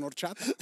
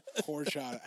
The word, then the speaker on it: horchata: Horchata. I hate it when they say horchata. Don't pronounce it, dude. It sounds like whore.